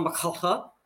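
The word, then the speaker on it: MacLachlan: why would you associate Kyle McCullough? – Oh, because I, I, I could never pronounce his name. Kyle McLaughlin.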